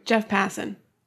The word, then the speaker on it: Passan: Jeff Passan.